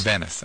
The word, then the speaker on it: Venice: P-O-R-T-I-A, Porsche. – Really? – Yeah. – Is that how they spell it? – Merchant of Venice. She was the... – Ventus? – Venice, I beg your pardon. – All right. See, you mispronounce things yourself, Hotshot.